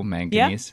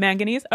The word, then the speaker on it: manganese: Manganese. – Yeah, manganese. All right, that's good. Yeah, so manganese dioxide, and this is used as a decolorizer back when they were doing this.